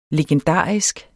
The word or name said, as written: legendarisk